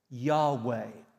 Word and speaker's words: Yahweh: These letters are Yod, He, Vod, He. Yod, He, Vod, He, which pronounced sounds something like Yahweh.